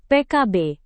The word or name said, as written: PKB